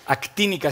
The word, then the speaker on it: actínica: O la luz actínica, actínica, así lo dije bien, que es una luz que ni se ve ni se siente, pero se percibe perfectamente.